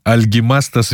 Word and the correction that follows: Альгимантас: Видугирис. Альгимантас Видугирис. Или Альгис Видугирис, чаще его так называют, то, что Альгимантас сложновато произносить.